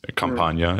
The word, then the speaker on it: Campania: It's Campania, is it how to pronounce it? Yeah. – Yeah, correct. Yeah.